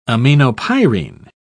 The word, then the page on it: aminopyrine: British English